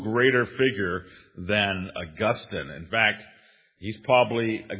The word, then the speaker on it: Augustine: When we're referring to the famous Augustine, the conventional pronunciation is not Augustine, but Augustine. So now you know.